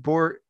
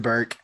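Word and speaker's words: Bourke: Anywho, they also have Dooney and Bork. Bork, is that how you say it? – Dooney and Burke. – Is it Burke? – Yeah.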